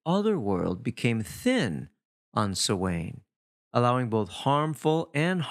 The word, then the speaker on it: Samhain: Samhain, which is spelled S-A-M-H-A-I-N, that means the end of summer.